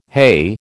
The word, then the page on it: heigh: American English